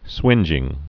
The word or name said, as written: swingeing